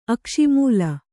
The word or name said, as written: ಅಕ್ಷಿಮೂಲ